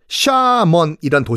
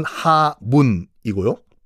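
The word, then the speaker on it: 샤먼이란 (厦门): 복건성 보면은 샤먼이란 도시가 있을 거예요. 우리 발음은 하문이고요.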